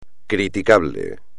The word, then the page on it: criticable: espagnol